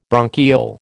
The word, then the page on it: bronchiole: American English